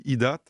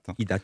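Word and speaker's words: IDATE: Mais cette actu immédiate, vous êtes le président de LightDat DigiWorld Institute, j'espère que je prononce bien. Qu'est-ce que ce think tank, ça sert à penser? – Alors, on dit IDAT. – IDAT, merci.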